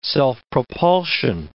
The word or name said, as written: self-propulsion